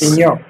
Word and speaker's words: Maignan: Allora, la N finale non la devi neanche leggere. La N finale non la devi neanche pronunciare. Okay. Se ci fosse una E alla fine, pronunceresti anche la N finale. La E alla fine non c'è, quindi quella lettera lì te non la devi pronunciare. Però la AI si dice E, Perché me, cioè ma, me, è scritto mais, mignon. Quindi me, quindi è mignon.